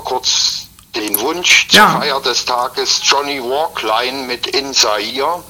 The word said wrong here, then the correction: Wicklin: Ich hoffe, ich habe den Namen richtig ausgesprochen. – Ja. – Wicklin. – Johnny Wicklin heißt er.